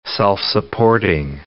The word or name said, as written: self-supporting